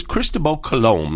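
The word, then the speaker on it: Cristóbal Colón: Everything's referred to as Cristobal Colón. That's the correct pronunciation.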